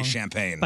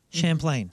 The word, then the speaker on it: Champlain: Yeah, I wanted to say Champagne. – By saying it right. Champlain.